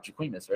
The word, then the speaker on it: Jacquemus: Yeah, we're talking about the Jacquemus. But how do you say it? – A Jacquemus. – Yes. The collab that they're putting out with Nike. It's a footwear. – Hold on, 1 second. I hope everyone that's listening could hear my eye roll. – Jacquemus, yes. – It's the best singer alive, Jacquemus, right? – It's Jacquemus.